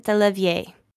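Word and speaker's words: DeLarverie: Johnson, Sylvia Rivera, and is it Stormy De La Vera? – She pronounces it Delavier.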